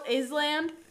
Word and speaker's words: island: When I was little, I had a hard time really realizing that island, it's spelled Island. – Yeah. – But I had the hardest time realizing that it was pronounced island.